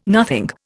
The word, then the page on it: nothing: Indian